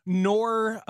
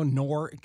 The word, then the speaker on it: Knorr: I had some excellent Knorr French onion dip. You know, Knorr, Knorr, K-N-O-R. – Yeah, the K is silent, yes. – Like knife.